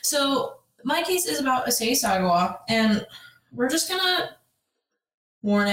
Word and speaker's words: Issei Sagawa: Issei Sagawa. Did I say it how I was supposed to? – Yeah, I think it's Issei Sagawa.